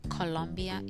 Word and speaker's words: Colombia: The writing Colombia is exactly how you pronounce it. Colombia. Not with the U, but with the O.